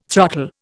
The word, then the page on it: throttle: Indian